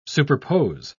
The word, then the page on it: superpose: British English